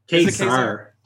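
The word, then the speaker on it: Ka-Zar: Kazar.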